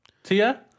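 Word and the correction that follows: Tchia: Chia. Just imagine the T is silent. Chia.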